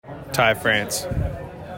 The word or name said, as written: Ty France